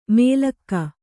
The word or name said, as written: ಮೇಲಕ್ಕ